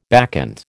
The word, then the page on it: bacchant: American English